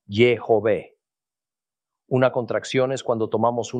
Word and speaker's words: Yahweh: La pronunciación Yahweh, por tanto, es menos probable porque tiene dos sílabas y emplea solo dos sonidos vocálicos. Más correcto es probablemente Yehové.